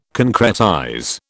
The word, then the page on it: concretize: British English